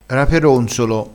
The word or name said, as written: raperonzolo